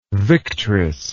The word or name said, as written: victress